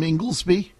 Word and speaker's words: Inglesby: And to help lead our federal testing program, I've tapped Dr. Tom, I hope I pronounced it, Inglesby, correct? Is that right, Jeff? – Yeah. Tom, will you check and see what that doctor's name is, Nicole? Is it Tom Inglesby? – That's okay, yep, I'll check.